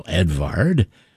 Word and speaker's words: Edvard: We've got music of Edward Grieg right now, Edvard, we should say that properly,